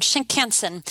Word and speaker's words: Shinkansen: It's Shinkansen? Am I saying that wrong? – Yeah, no, that's right. It literally means new trunk line. – New trunk line. Shinkansen.